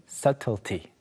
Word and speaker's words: subtlety: 자, 처음으로 나오는 것은 미묘함 또는 중요한 세부 요소들을 가리키는 subtlety, subtlety. B가 발음이 되지 않아요.